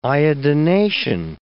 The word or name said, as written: iodination